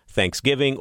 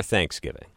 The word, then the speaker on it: Thanksgiving: There are two accepted ways of saying Thanksgiving or Thanksgiving, and I say them both. – No. – Yeah. Depends on the... It depends on the context on which syllable you're giving the emphasis to.